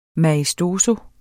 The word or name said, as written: maestoso